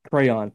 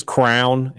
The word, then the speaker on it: crayon: Crayon. – Crayon. That's correct. – Is Anybody who says crown is wrong.